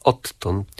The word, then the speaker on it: odtąd: Odtąd? – To prawda. Pisownia inaczej, wymowa inaczej, ale w tej formie, którą pan przywołał, wymawiamy podwójne t odtąd, mimo że w zapisie ortograficznym mamy d i t.